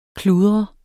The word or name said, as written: kludre